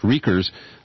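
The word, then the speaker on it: Riechers: And his name is pronounced Rikers.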